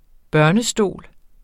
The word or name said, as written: barnestol